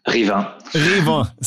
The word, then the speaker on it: Rivain: Rivain, was that correctly pronounced? – Rivain.